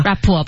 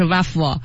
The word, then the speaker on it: rap: 今天晚上记得去写 rough 歌词啊，把今天知识点都写进去啊。 – rap 不是 rough，注意发音准确哈。